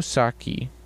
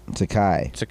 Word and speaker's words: Sakai: Versus Augusto Saki. – Sakai.